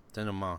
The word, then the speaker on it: denouement: Denouement? – Denouement, yeah.